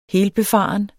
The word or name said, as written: helbefaren